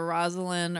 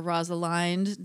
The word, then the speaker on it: Rosalind: The precursor to like As You Like It and the Rosalind or Rosalind, depending on how you want to pronounce it, character is like developed.